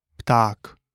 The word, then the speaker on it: pták: A is pronounced like the E in father. An example is krása, meaning beauty, or pták, meaning a bird.